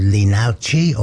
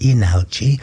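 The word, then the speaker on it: Leenalchi: If you came to WOMAD last time, there was a group from Seoul, and they were called Linauchi, or they actually pronounce it Inauchi, they were absolutely fascinating.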